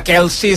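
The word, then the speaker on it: Kelce: Escoltàvem Taylor Swift, la parella de l'amic Kelsey, que hem de pronunciar Kelsey, o Kelsey. Perquè vaig veure fins i tot que en el podcast que tenen amb el seu germà feien conya de veure com havien de pronunciar el seu cognom, i amb el seu pare per allà al mig també, i crec que al final van acabar coincidint que Kelsey seria una bona pronúncia.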